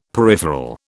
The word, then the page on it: peripheral: British English